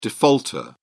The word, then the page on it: defaulter: British English